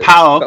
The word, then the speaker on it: PAOK: PAOK, that's how you pronounce it, yeah.